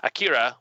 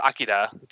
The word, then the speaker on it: Akira: And while he's getting checked out by doctors, it's discovered that his psychic abilities are similar to those of Akira or Akira, to pronounce it more accurately, but less westerny.